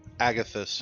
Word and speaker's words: Agathys: So he is also going to cast Armor of, how do you pronounce that? Agathys? – Agathys, yeah. – Yes, at a third. – Some people say Agathys, some people say Agathys.